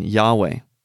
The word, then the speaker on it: Yahweh: Very striking is the abandonment of the traditional Lord for the Tetragrammaton, and also the traditional Jehovah, in favor of the historical pronunciation Yahweh.